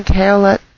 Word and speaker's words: Caoilte: He uses the name Kaolet Skytower in Second Life, which I'll just pronounce as it looks to make it easier for you to find him and his music. It's spelled C-A-O-I-L-T-E, Skytower. I know he pronounces it in the Celtic way, but he won't mind it all at all if it helps you find his concerts easier.